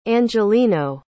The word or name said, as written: angelino